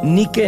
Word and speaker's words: Nike: Pronunciado como Nike en inglés, Nike en español es el nombre de la diosa griega de la victoria.